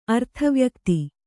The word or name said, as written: ಅರ್ಥವ್ಯಕ್ತಿ